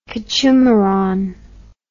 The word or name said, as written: kechumaran